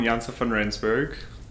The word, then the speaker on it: Janse van Rensburg: So my name is Sean Jantz van Rijsenberg.